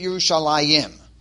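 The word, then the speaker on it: Yerushalayim (ירושלים): We pronounce it Yerushalayim, as if there's a Yud, but there is no Yud.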